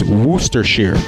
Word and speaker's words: Worcestershire: And if you ask English people, they say the first R you don't hear, and it's Worcestershire. – Worcestershire. – Worcestershire. So if anyone has any like confirming evidence, but apparently English people say that Americans are saying it wrong, and that first R doesn't go, like, you don't say it.